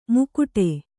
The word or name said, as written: ಮುಕುಟೆ